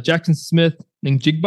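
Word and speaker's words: Jackson Smith-Njigba: It didn't help Ohio State with Jackson Smith Ningjigba.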